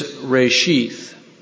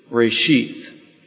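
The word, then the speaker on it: reshith (ראשית): The Hebrew word is reshith, reshith, and it's spelled R-E apostrophe S-H-I-Y-T-H. R-E apostrophe S-H-I-Y-T-H.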